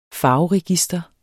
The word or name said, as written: farveregister